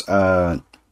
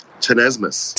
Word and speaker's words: tenesmus: First word is, Tenesmus. – Tenesmus. – Tenesmus. That is spelled T-E-N-E-S-M-U-S, and it is a noun.